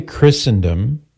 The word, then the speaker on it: Christendom: So we say Christendom with a silent T because some words in English have a silent T, like Christmas.